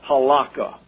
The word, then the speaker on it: halakha: or halakha would be a more precise pronunciation.